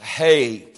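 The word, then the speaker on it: hey (ה): We would have it H-E, but it's pronounced hey.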